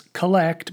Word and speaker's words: Collect: At the opening prayer of Mass, which we call the Collect, it's spelled the same as collect, but pronounced collect, comes from the Latin word collecta, meaning to gather.